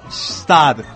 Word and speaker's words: Gstaad: Stad. – G-S-T-A-A-D. Claro. – G-S-T-A-A-D. – Es como el nombre Stad. De una placa de video. – Pero Bastad. – Lo pronuncié bien, me dicen.